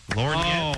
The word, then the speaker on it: lorgnette: Lorgnette.